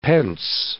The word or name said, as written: pence